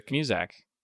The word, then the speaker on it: Muzak: M-U-Z-A-K. Muzak? How do you say it differently? – Music. Muzak. No, it's Muzak.